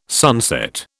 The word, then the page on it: sunset: British English